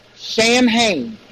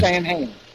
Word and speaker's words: Samhain: Samhain is the pronunciation. And it doesn't sound like you spell it. – Okay. – Samhain.